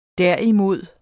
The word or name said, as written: derimod